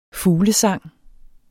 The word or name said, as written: fuglesang